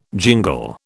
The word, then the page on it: jingle: British English